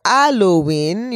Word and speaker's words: Halloween: In the Haitian Creole culture, Halloween, you know, the H is silent, is considered fête de job.